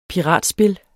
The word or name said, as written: piratspil